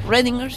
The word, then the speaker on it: Schrödinger: Schrödinger. – Bien. Schrödinger, sí.